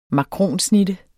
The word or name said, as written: makronsnitte